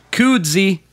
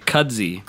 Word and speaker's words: Koodzy: And today we have Koodzy. Did I say that right? – Hello, yes, you did. – All day I've been saying Kudzy. – Kudzy. – I had to be, like, corrected, you know what I mean?